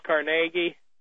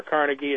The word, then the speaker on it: Carnegie: You know, I said it at the conference the other day, you know, that there's the old saying about Dale Carnegie, or Carnegie as some pronounce it.